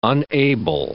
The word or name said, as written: unable